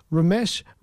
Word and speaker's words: Romesh: Uh, it stars uh, Ramesh. Ra- sorry, I got to pronounce his name properly. Um, it stars Romesh Ranganathan, and he's a comedian.